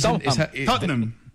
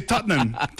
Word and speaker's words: Tottenham: Tottenham. Tottenham. Son dos sílabas. – Ajá. Es difícil, ¿no? – Nosotros los británicos. Pero... – ¿Solo dos sílabas? – Sí, Tottenham.